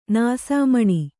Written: ನಾಸಾಮಣಿ

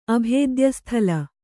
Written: ಅಭೇದ್ಯಸ್ಥಲ

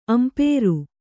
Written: ಅಂಪೇರು

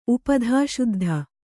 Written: ಉಪಧಾಶುದ್ಧ

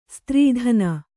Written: ಸ್ತ್ರೀದಾನ

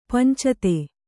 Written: ಪಂಚತೆ

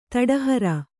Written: ತಡಹರ

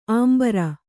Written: ಅಂಬರಿ

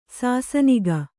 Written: ಸಾಸನಿಗ

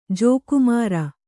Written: ಜೋಕುಮಾರ

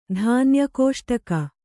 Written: ಧಾನ್ಯಕೋಷ್ಟಕ

